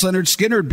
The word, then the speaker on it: Lynyrd Skynyrd: Pronounced Lynyrd Skynyrd, because of that song.